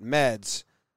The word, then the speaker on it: Meads: Yeah, he put on the back of his jersey, Trev didn't hear this interview yet, M-E-A-D-S, but he pronounces it Meds, but the announcer called him Meads, and he didn't like it.